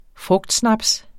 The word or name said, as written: frugtsnaps